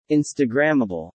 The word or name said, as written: instagrammable